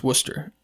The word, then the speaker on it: Worcestershire: It's from England, but it's Worcester, that's how you pronounce Worcester. W-O-R-S-T-E-R. Worcester. – T-E-R-T, right?